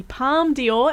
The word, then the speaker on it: Palme d'Or: This is Movies, Movies, Movies, and up next we're talking to actor, writer, and performer Mandela Matia about starring in the Palme d'Or, am I saying that right? – Yeah.